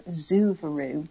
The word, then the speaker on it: Suvero: It was in the collection of Emma Henriette Schiff von Zuvaru. I hope I've pronounced that right. The surname is spelt S-U-V-E-R-O, Zuvaru.